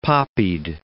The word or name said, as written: poppied